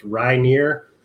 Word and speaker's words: Reinier: Yeah, like Nate said, well, first of all, my name's Scott, and it's pronounced Reinier. So it's like rye bread, and then near, not far.